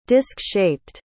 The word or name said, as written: disk-shaped